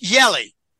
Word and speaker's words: Jelle: S-T-R-A. He's Dutch. His first name is Jelly, probably pronounced Yelly. J-E-L-L-E. Yelly Zylstra.